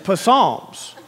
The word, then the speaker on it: Psalms: Well, first of all, how do you pronounce Psalms? How can you be so sure? It starts with a P. It's Psalms.